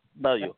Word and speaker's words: Bellew: Bellew.